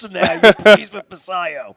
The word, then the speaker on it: Basallo: Baltimore Positive, I got a text message this morning that Basallo. – Basayo. – Okay, yeah, I want to say it right. Basayo.